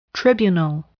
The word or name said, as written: tribunal